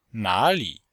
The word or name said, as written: na3li3